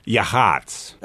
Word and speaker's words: Yachats: Yahats. Y-A-C-H-A-T-S. It rhymes with hots.